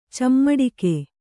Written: ಚಿಮುಟಿಗೆ

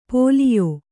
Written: ಪಲ್ಯ

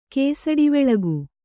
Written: ಕೇಸಡಿವೆಳಗು

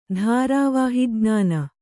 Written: ಧಾರಾವಾಹಿಜ್ಞಾನ

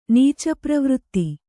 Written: ನೀಚಪ್ರವೃತ್ತಿ

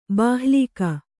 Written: ಬಾಹ್ಲೀಕ

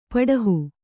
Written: ಪುಡೆಹ

